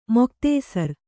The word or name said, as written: ಮುಕ್ತಾಸಾರ